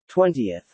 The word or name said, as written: Twentieth